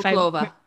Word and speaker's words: Monclova: I'm sorry if Monclova. – Monclova. I'm sorry I mispronounced that.